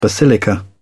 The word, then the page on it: basilica: British English